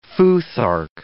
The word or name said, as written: futhark